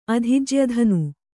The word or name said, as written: ಅಧಿಜ್ಯಧನು